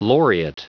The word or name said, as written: laureate